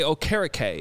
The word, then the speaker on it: Okereke: That you saying carrot cake, though, was perfect, because that's why I'm saying it correctly. – Yeah. – I just think carrot cake. – Oh, carrot cake. – But I say Okereke instead, and that's how I know to pronounce it correctly.